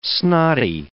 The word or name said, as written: snotty